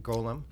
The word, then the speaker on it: Golem: I'm saying that correctly. Golem. – Not Gollum from Lord of the Rings.